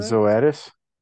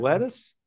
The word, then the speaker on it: Zoetis: Zotisus? Did I mispronounce that? – Zoetis. Nailed it. – Zoetis? – Yeah, it's I know exactly.